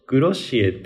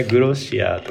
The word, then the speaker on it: GROSSIER: いや、なんか、すごい面白くて、グロシエっていう GROSSIER っていうブランドがあるんですけど、この前グロシエのファウンダーのポッドキャスト聞いてたら、みんなが発音間違えるから困るって言ってて、みんなグロシアとか言っちゃうんだけど、本当はグロシエだよとか言ってて。